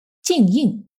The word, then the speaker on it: 金英: 清代中叶之前，明清时期的中国官方标准语均以南京官话为标准。《红楼梦》故事的发生地金陵城指的就是南京，而南京官话里“ 金英” 两个字读作“ 静应”。